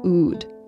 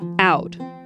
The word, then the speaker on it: oud: It's called the oud, or oud. I've also heard it pronounced oud.